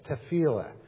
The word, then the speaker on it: tefillah: it's tefillah. Did I say that right? Okay, tefillah.